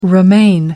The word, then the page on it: romaine: American English